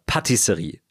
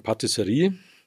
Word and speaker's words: Patisserie: Ich habe ein paar Freunde, die auch in der Gastro arbeiten und die sagen nicht zum Beispiel Restaurant, sondern die sagen Restaurant. Die sagen auch nicht Dessert, sondern die sagen Dessert oder Patisserie. Also immer mit der Betonung auf der ersten Silbe.